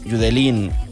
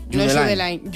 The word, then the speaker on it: Judeline: Pues entonces Judeline. Judeline. Si es de Barbate, Judeline.